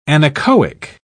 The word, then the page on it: anechoic: British English